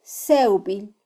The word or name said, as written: sewbil